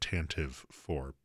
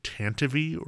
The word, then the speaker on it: Tantive IV: I pronounce it Tantive IV, but the radio show production of the original Star Wars back from like the 70s, it pronounces it Tantive or Tantive, depending on which actor is saying it.